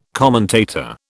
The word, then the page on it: commentator: British English